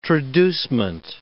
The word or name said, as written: traducement